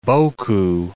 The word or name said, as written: beaucoup